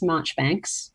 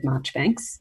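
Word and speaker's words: Marjoribanks: Um, it's called Miss Marchbanks, but it's spelt Miss Marjorie Banks, but it said Marchbanks mm.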